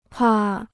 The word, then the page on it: 怕: pà